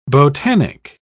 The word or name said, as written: botanic